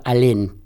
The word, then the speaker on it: Alén: Y este Cuitiño tenía un hombre de confianza que se llamaba Alén, con N final y acento en la E, que tenía una pulpería cerca de donde yo vivo por ahora, ahí en Balvanera.